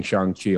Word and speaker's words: Shang-Chi: I was on live with Joe before this, and he was like, you better be saying Shang-Chi on there. He was like, that's how you pronounce it.